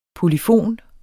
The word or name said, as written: polyfon